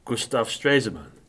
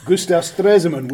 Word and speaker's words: Gustav Stresemann: Gustav Stresemann. – That's right. – Yeah. – Gustav Stresemann, which we've only just discovered how to pronounce properly after teaching it for 20 years.